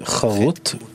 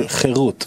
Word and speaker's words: Cherut: Do not pronounce it Charut with that pronunciation under the Chet, but rather Cherut, freedom, with Tzereh under the Chet, since this freedom is found in the Torah.